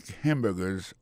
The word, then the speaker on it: hamburgers: N'a pas remarqué que dans le mot hamburger, ça a comme tu as dit, il y a trois syllabes là-dedans. Quand tu appuies et puis plus ça, c'est sous premier syllabe là. I make hamburgers all day.